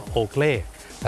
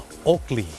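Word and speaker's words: Oakley: คนไทยออกเสียงว่าโอเคลแต่เจ้าของภาษาไม่ได้ออกเสียงว่าโอเคลครับเขาออกเสียงว่าโอกลีโอกลี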